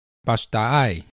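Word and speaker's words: pasta'ay: 矮灵祭的塞夏族语念作巴什达艾。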